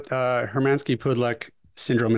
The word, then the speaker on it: Hermansky-Pudlak: Okay, well, let's talk about, I want to talk a little bit about Hermansky-Pudlak syndrome, and I'm sure I butchered that.